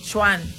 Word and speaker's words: Sean: Se pronuncia Chuan. Pero se escribe Sean.